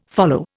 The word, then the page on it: follow: Indian